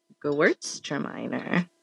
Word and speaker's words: Gewürztraminer: And it's giving Gewürztraminer. – Gewürztraminer. – Yeah, you pronounced it really well.